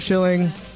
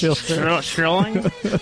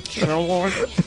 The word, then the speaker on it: Schilling: Dave Schilling, I just said your name wrong. – Thanks. Thank you. It's pronounced Schilling. Schilling. This was lovely.